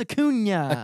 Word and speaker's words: Acuña: Come on, it's Acuña. – Acuña. – Just pretend like it's NYA at the end.